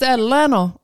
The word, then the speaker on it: Atlanta: It's Atlanta. That's how they say it out here. Atlanta. Atlanta. Atlanta. – Yeah. You don't even got a T in there. It's just Atlanta.